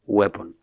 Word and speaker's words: Weapons: ¿Cómo se dice? ¿Whipple? – Weapon. – Bueno, pues Whipple.